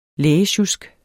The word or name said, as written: lægesjusk